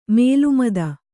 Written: ಮೇಲ್ಮಿತಿ

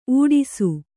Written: ಊಡಿಸು

